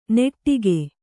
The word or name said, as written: ನೆಟ್ಟಿಕೆ